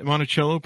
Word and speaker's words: Monticello: And Monticello pronunciation correct, I think? – Absolutely. You okay.